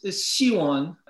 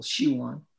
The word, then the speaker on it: Siwon: Yeah, it's Siwon, accent on the first syllable, Siwon, which also happens to be said my daughter's, you know, middle name.